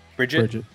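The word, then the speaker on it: Brijite: Brigitte West, is that how you pronounce her name? It's B-R-I-J-I-T-E. Bridget? – Bridget, yeah.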